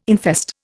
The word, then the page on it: infest: Indian